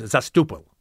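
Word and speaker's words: Zastoupil: I mean, actually, I've always liked, that's almost the Ellis Island spelling, pronunciation. – Zastapil? – Yeah. The real pronunciation is Zastupil. – Yeah, which I think is cooler.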